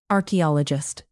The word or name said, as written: archaeologist